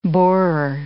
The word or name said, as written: borer